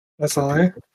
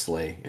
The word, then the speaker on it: SLA: I would like to run for SLA. – Yeah, it's pronounced Slay Industries.